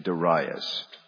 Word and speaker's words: Darius: His name is Darius, or I prefer the pronunciation Darius.